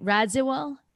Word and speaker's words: Radziwill: So Carol, am I saying her name right? Radziwill? – Yeah, Radziwill.